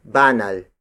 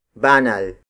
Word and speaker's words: banal: When measuring the weight of objects, the basic unit of native measurement is banal. Banal. Banal. Banal is spelled as B-A-N-A-L.